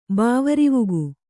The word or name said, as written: ಬಾವರಿವುಗು